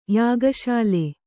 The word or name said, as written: ಯಾಗಶಾಲೆ